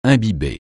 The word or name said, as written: imbiber